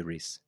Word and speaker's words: Reiss: R-E-I-S-S. – Yeah. – How would you say that shop? – I'd say Reese. – Oh, okay. I've been saying Rice. German would put I, really? – Well, E-I as the I sound.